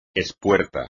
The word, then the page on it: espuerta: espagnol